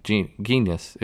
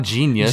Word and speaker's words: genus: And genus, what is it? – Genus. – It's not genius.